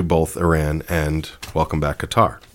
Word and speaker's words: Qatar: Am I saying that right? Qatar? – Mm-hmm. Yes. – Qatar, welcome back, Qatar.